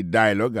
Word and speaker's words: dialogue: Daily Dialogue, alors, yo pesé plus sous premier syllabe là. Daily Dialogue. Alors, accent tombé sous premier syllabe là.